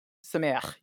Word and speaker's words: Sameach: Sameach, yeah. – Sameach, huh. With that sound at the end.